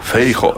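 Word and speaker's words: Feijóo: Jā, runājot par eh, fonētiku, um, tautas partijas līderi uh, sauc Feiho. – Feiho. Es atvainu. – Nu. Tas, uh, eh, Pierakstam. – Kas latviešu tiek izrunāts kā ja, tas spāņu valodā parasti kā.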